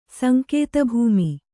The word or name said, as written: ಸಂಕೇತಭೂಮಿ